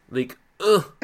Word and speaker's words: Ligue 1: Ligue 1. All right, 2 questions for you. – I feel like you're not saying that right. It's Ligue 1.